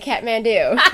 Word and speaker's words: Kathmandu: Kathmandu? – Oh, that's right, Kathmandu.